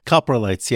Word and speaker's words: coprolites: human coprolite? – Coprolites, yeah.